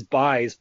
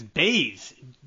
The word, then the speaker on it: Buys: I'm almost positive it's Bays. JP, yeah, okay. Bays. You say it like the U is an A.